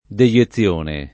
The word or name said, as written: deiezione